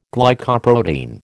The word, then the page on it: Glycoprotein: American English